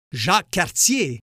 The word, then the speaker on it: Jacques Cartier: By the way, Jacques Cartier is pronounced Jacques Cartier in my neck of the woods.